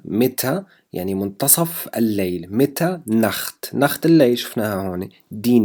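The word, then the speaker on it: Nacht: دي نخت، دي نخت، نخت. التسي والها هون تلفظ مثل حرف الخاء في اللغة العربية.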